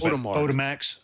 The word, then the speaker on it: Odemax: Odemax, O-D-E-M-A-X.